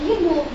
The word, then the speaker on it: Янов: Янов, да. И ударение тоже не на я, а на о.